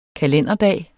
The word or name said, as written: kalenderdag